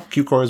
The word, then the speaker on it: Cukor: they wanted George Cukor and... I probably said his last name wrong. – No, Cukor is right.